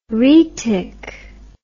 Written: rhaetic

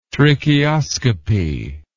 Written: tracheoscopy

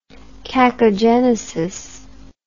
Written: cacogenesis

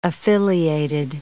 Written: affiliated

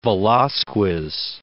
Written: Velazquez

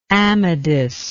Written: amadis

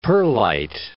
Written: perlite

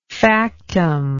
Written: factum

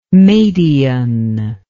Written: madian

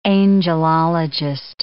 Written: angelologist

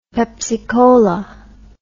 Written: pepsicola

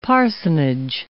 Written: parsonage

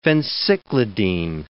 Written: phencyclidine

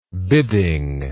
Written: bidding

